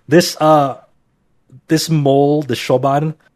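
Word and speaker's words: Siobhan: mole named Shoban S I O B H A N I think that's how it's, it's pronounced is Shoban and uh eventually this mole person uh,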